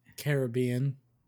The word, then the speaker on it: Caribbean: How do you say it, Caribbean or Caribbean? – Caribbean. – Caribbean, okay. I'm sure there's some people out there who say Caribbean, but – I say Caribbean.